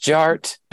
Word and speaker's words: DJ Art: And my name is Jared, aka DJ Art, with two T's for a double dose that tink tink the D is silent, so it's just Jart.